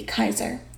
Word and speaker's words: Caesar: Or if you are going to say it in Latin, it'd be Kaiser. Because the way things are pronounced properly, Julius Caesar would have been Julius Kaiser, because they don't pronounce their J's. It's an I sound.